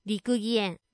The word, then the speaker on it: 六義園: 漢数字の六に義、よしとも読みますね。義理のとか、そういう義にそのと書いて六義園と読むんですけれども、この六義園はですね、和歌の中に読まれる風景とかを再現しているらしいんですよ。で、もともとね、大名だった柳沢義康さん、川越藩主が作ったらしいです。